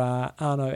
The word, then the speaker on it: Arnaud: So if you're keen to go and do a support crew role for Arno at Ultraman Canada, the dates are 3rd, 4th, 5th of August, get in touch with him. Just his name is spelled A-R-N-A-U-D, and then Sulikov.